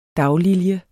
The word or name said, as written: daglilje